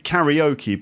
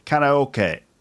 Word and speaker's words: karaoke: We pronounce it karaoke, but in Japan it's karaoke.